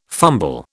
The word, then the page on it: fumble: British English